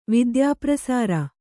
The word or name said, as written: ವಿದ್ಯಾಪ್ರಸಾರ